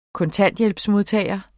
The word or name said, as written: kontanthjælpsmodtager